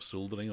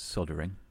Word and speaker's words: soldering: soldering on. – Okay, just two points there. You're saying it wrong. It's soldering, clearly.